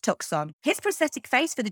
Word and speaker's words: Tucson: At his first casting as an American, he actually thought Tucson was pronounced Tucson, which, to be fair, so did I for a very long time until I realised that it's not pronounced phonetically.